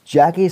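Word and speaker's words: Jacie: Jackie Scott, I think that's how you pronounce it. It's J-A-C-I-E. So yeah, I'm going with Jackie. Jackie Scott,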